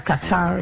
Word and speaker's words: Qatar: Group B, Canada versus Qatar, or Qatar, if you want to pronounce it like that, 5 p.m.